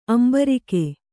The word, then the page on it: ಅಂಬರಿಕೆ: kannada